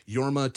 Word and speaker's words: Jorma: So it was, so I think his name, is it pronounced Jorma or Yorma? – It's spelled Jorma. I watched a thing. Similarly, I was like, I'm going to say his name a bunch. So I watched John Mulaney introduce him on a panel, and he referred to him as Yorma Taconi.